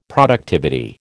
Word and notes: productivity: American English

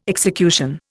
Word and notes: execution: Indian

